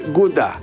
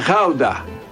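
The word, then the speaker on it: Gouda: Επειδή έμενε ένα διάστημα στην Ολλανδία, το G προσφέρεται who και όχι γκου, όπως τα αγγλικά. Όπως και το τυρί γκούντα. Είναι χάουντα. Υπάρχει άλλος και μονίμη πολύ.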